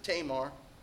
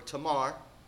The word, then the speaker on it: Tamar: The second thing we'll look at is the shrewdness of Tamar, or Tamar, as David correctly pronounced.